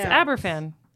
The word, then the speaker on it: Aberfan: And that's how that's how you say it. It's Aberfan.